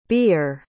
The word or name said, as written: ber